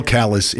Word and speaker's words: Calais: The real Callus in France is Calais, but no, not in Maine. It's Callus.